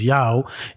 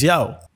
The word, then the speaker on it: Yaw: It's dope, bro, that you, for you, you said, no, my name is Yao. If you don't know how to say it, maybe you should think about your life, you know, because my name is Yao.